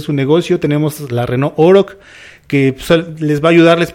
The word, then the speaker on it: Oroch: En este caso, platícanos del portafolio de unidades que tienes para el segmento de las empresas como Renault Oroch, que tú me decías que se pronuncia así, Renault ¿sí? – Oroch,